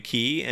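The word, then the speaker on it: Cay: A perfect day at Castaway K. Or key, depending on how you want to pronounce it. I say key. I think it's key.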